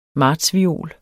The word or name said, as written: Martsviol